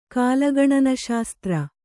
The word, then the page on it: ಕಾಲಗಣನಶಾಸ್ತ್ರ: kālagaṇana śastra